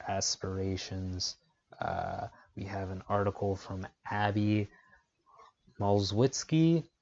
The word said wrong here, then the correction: Malzewski: Malzewski, excuse me, very horrible pronunciation.